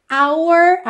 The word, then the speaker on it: our: Esse our, aí, nosso, nossa, é, frequentemente também vai ser pronunciado como se fosse a letra R. Our way, ok? R, é a letra R em inglês, certo?